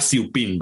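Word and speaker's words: ciuppin: Era, se llama así, igual, en vez de chupín se llama siupín, ¿verdad? Eh, chupín se, pronuncia en italiano.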